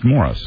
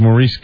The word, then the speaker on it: Maurice: It's spelled Maurice. It's pronounced Morris. – Oh, I always thought it was Maurice Gibb.